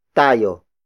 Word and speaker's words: tayo: Mamaya is spelled as M-A-M-A-Y-A. It means later. Again, that's kami. The next pronoun is tayo. Tayo. Tayo. Tayo is spelled as T-A-Y-O.